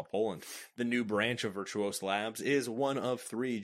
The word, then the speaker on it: Virtuos: Development service provider Virtuous. No. That's not Virtuous. – I kind of pronounce it Virtuous. I guess you could say it's Virtuos. – Yeah. We're going to go with Virtuos.